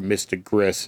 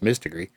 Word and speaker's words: Mystigree: I would also like you to give a look-see at the latest Mystigree Art Pack, or Mystigris, as some people call it. It's pronounced Mystigree.